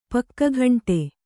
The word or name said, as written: ಪಕ್ಕಗಂಟೆ